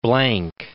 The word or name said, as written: Blanc